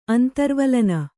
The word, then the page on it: ಅಂತರ್ವಲನ: antarvalana